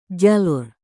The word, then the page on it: jalur: Indonesian